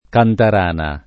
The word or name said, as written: Cantarana